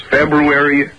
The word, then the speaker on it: February: Well, actually, there's three or four different acceptable pronunciations. – Well, what are they? – What are the acceptable pronunciations? – February. – Oh, February is acceptable.